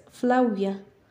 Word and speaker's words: Flavia: Sabes, la V se supone que se pronuncia como la W. ¿Qué? En Flavia se supone que lo pronuncias Flavia.